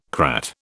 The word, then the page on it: crat: British English